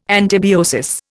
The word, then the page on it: antibiosis: Indian